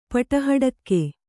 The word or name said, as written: ಪಟಹಡಕ್ಕೆ